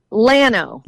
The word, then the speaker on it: Llano: Llano.